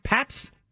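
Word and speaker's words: Pabst: Am I saying that right? Paps? – That is correct.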